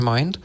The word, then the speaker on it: Mynd: No i tutaj Teufel nie chce być gorszy i wpadli na pomysł, że zaoferują głośnik Bluetooth Teufel Mind. Chyba tak się to czyta, bo pisze się MYND, Mint, więc przypuszczam, że nadal czyta się Mind.